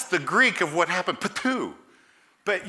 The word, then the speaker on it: ptuo: The word is transliterated into English, spelled this way, P-T-U-O, and it's pronounced patu.